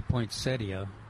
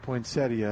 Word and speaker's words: poinsettia: I've said that wrong for 45 years, but... – What do you say? – Well, I say poinsettia. – Poinsettia, and then some people say poinsettia.